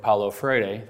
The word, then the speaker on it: Paulo Freire: since I said it, I think, correctly with the Portuguese. I'll spell it for you because he's going to come up a whole bunch of times. It is F-R-E-I-R-E. Paulo is spelled P-A-U-L-O. Paulo Freire.